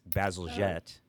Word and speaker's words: Bazalgette: and I said his name wrong the first time, but it's Bazalgette. Is that right? – Yes.